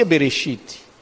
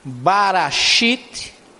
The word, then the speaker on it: Bereshit: Por exemplo, Bereshit, se pronuncia Bereshit, mas não tem vogal. Aí você não sabe se é Bereshit, Barashit, porque pode ser Barashit, e se disser Barashit, eu estou dizendo, construiu em seis, Deus criou em seis dias, olha aí.